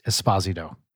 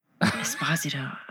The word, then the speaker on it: Esposito: His last name is pronounced Esposito. – Esposito. Oh, man. – One of his parents is Italian. His name is pronounced Giancarlo Esposito. And I'm not kidding.